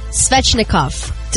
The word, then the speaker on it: Svechnikov: Andrei Svechnikov. Did I say that right? – Yes, you did. Right! You got it right.